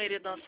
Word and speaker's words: Eridan: Quem fala é a Luciana, eu falei em nome do Banco Santander, eu falo com o Eridan Salles. – Isso. – Tudo bem? Falei o nome da senhora certo? – Tudo bem. – Falei certo o nome da senhora, Eridan? – É, Eridan.